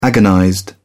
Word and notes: agonized: British English

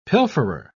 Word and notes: pilferer: British English

